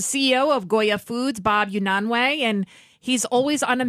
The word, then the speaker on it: Unanue: And, and I understand a million pounds worth of food for uh, Poland and the Ukrainians Tell us, uh, Bob Unanue. I say it right. Yes.